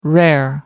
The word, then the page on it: rare: American English